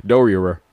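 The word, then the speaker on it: Doerrer: Director of Communications, Community Engagement, and Marketing for the district, Michael Dorier. It's a lot of R's. D-O-E-R-R-E-R. – Oh, I don't know how to pronounce that name. – Yes. Dorier.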